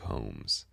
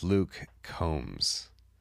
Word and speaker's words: Combs: Luke Holmes. I had no idea it was Luke Combs. Luke Combs. It almost sounds like one word. Luke Combs. One word, two syllables.